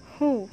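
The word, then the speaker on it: Hu (呼): Hu, pronounced like who, which is associated with the spleen.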